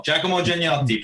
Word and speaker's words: Giacomo Giannotti: Did I pronounce that all right? – Pretty good, yeah. Giacomo Giannotti. Pretty good.